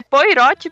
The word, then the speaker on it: Poirot: Na verdade é Poirot, porque só tem um R. É, então né? Se você Poirot for ler de verdade, ainda, né? É Poirot.